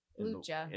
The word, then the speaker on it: Lucia: And Simona Tabasco as Lucia, or Lucha? I forgot how you say it. – Lucha.